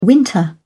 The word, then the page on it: winter: British English